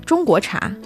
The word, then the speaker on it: 中国茶: How do you say Chinese tea? Is it 中茶? – 中国茶. – Ah, 中国茶. Okay.